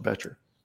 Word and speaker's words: Bettger: It's spelled B E T G E R, but la- the name is pronounced Betcher.